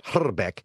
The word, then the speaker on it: Hrbek: Now, I know we don't make a big point of it on the air, and I'm going to say every broadcast, Kent Hrbek, Okay, but it technically that is a correct pronunciation, and man, did Musburger ever give me a hard time about that?